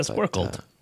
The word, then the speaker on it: squircled: It's a squirkled. – Yeah, squirkle. – Steve and I had a whole conversation about this before the show. How to actually pronounce this word. S-Q-U-I-R-C-L-E-D. Squirkled.